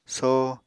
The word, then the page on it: soh: Apatani